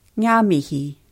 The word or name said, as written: nga mihi